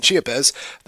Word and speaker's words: Chiapas: In Chiapas, am I pronouncing that right, by the way? – Yeah. – In Chiapas, the southernmost state of Mexico, the insidious entity is told to take form of a beautiful woman, sometimes dressed in white.